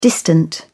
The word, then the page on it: distant: British English